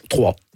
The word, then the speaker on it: Troyes: On dit Troyes? En France, comment on prononce ? – Trois. – Trois, mais voilà, ça s'écrit YES.